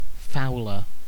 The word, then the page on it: fouler: British English